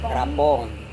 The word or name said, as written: rapohong